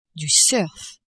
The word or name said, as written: surf